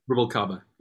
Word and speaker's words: Rubalcaba: It was Sullivan Fortner, Tigran Hamasyan, Gerald Clayton, Fred Hirsch, and someone who is a longtime collaborator of yours, Gonzalo Rubalcaba. Am I saying that correctly? – Rubalcaba, yeah.